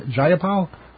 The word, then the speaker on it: Jayapal: Am I pronouncing it right? Jayapal? – Yes.